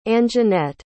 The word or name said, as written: Anjeanette